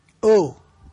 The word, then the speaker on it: eau: Pensate ai francesi, poverini, intanto hanno delle parole che si devono pronunciare in un certo modo, per esempio oh, cioè, oh, che dice, oh, che tu, oh, no, oh vuol dire acqua, scusa, oh. Ma si scrive E-A-U, ma si scrive E-A-U, E-A-U, E-A-U, vediamo un po' di A-U, che c'ho sete, no?